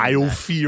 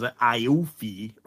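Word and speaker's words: Aoife: It's like A-I-O-F-E, but it's pronounced Stephanie. – It's A-O-I-F-E, which is Iofi or something, but it's one of them Irish names where, yeah, it's like Jennifer or something.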